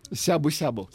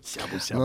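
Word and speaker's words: Сябу-сябу (しゃぶしゃぶ): Сябу-сябу. – Сябу-сябу. Но ну, Пишется шабу-шабу.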